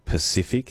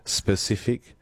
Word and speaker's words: Pacific: Rachel and Kira says, I have a friend who struggles with the word Pacific. He says specific.